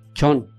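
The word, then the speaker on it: ǃXóõ: Chon. – ¿Así se pronuncia? – Sí. – Chon. – Chon. Se escribe X-O-O e inicia con la primera letra.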